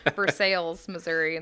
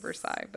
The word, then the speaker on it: Versailles: Just like it's Versailles, Missouri, instead of Versailles, but that's a whole nother show.